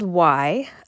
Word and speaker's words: WAI: So the platform is called Y. It's pronounced Y, obviously, as in why are we here? But it's spelled W-A-I, which stands for We Are Infinite.